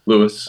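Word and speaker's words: Louis: Now, I know that you are the spiritual son of Edwin Louise Cole. No, how do I pronounce his middle name? – Lewis. – Edwin Lewis Cole, who I've read his books, and he's a phenomenal human, and I'm friends with his son, Paul, now.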